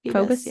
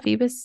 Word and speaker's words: Phoebus: Phoebus. – Phoebus. – Yeah. Phoebus.